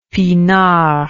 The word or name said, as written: pinard